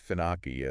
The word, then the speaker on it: finAPI: It's spelled FINAPI, Right. – but it's pronounced FinOpi as one word.